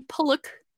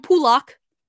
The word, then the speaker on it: Pulock: One, they couldn't pronounce Ryan Pulick's name right. It's Ryan Pulick. He kept saying, and there goes Ryan Pulock.